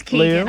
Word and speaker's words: Keoghan: Is it pronounced Keoghan? – It is Keoghan.